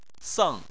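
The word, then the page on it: seng: tone 4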